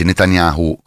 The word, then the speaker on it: Netanyahu: In realtà non era vero, si trattava solo di un effetto ottico, cioè Netanyahu anzi Netanyahu, come dicono quelli che sanno la pronuncia precisa, in quel momento aveva piegato la mano in maniera strana e quindi non si trattava di un video creato con l'intelligenza artificiale.